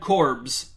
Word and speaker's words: Korbs: Number four, Lola and Dad Korbs. Yes, their last name is spelled and pronounced like corpse, but with a B instead of a P, although we only know that from one episode, episode 80.